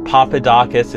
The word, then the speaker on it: Papadakis: Now, the final, the pick three, we've got Gabriela Papadakis, did I say that right? And Guillaume Cizeron. – That's correct. It's 2018. We're in the Olympics. Papadakis and Cizeron, the French team, their final performance is to Moonlight Sonata.